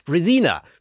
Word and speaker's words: Brezina: and if you want to pronounce it correctly in English, it's Brezina because you know I live Brezina.